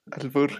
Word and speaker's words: albur: Y nunca la había entendí, ah, ¿cómo se dice? Arbur. Ah. – Albur. – Albur. Albur.